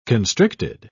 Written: constricted

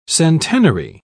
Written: centenary

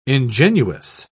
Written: ingenuous

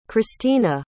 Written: chrystina

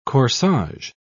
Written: corsage

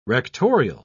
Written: rectorial

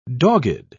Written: dogged

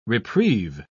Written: reprieve

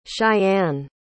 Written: shyanne

